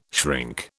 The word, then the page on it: shrink: British English